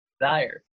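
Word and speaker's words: Kizzire: Kizari is actually how you say it.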